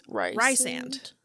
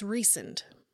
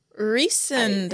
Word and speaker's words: Rhysand: So it's spelled R-H-Y-S-A-N-D, which you would think is Rysand. Yeah. It's not. It's Rysand. – Rysand.